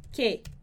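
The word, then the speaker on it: quejándoos: Então, eu vou falar quejando-os, quejando-os. Só que eu tenho que manter a sílaba forte no mesmo lugar, então eu também vou colocar um acento aqui nesse A, quejando-os.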